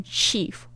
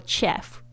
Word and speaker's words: chef: 这个 chef 大家特别注意一下，那个 ch 讲很多次，这个是很特殊的发音，是 sh 的声音，chef 不要念成 chief 或 chef，不要。